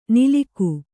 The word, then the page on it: ನಿಲಿಕು: Kannada